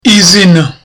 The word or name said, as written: izina